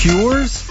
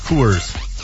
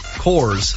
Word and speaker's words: Coors: So it's Cures? Coors. – Coors. – Coors. – Coors. I just call it 20th and Blake. It's easier. – I would say it rhymes with tours, and you can't say that word either, so we just, there's nowhere to go here.